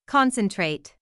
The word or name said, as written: concentrate